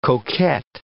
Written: coquette

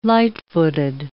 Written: light-footed

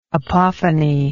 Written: apophonic

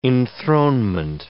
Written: enthronement

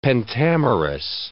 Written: pentamerous

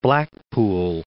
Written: Blackpool

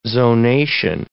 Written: zonation

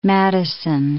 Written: Madison

